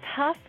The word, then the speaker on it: Hough: It is Hough like tough. – Hough. Okay, great. – Yeah, Karen Hough. – And it is spelled H-O-U-G-H, so that makes a whole lot of sense.